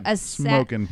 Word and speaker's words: Smokin': Smokin'. You're adding a G. – Smokin' Aces. – No hard G, Casey.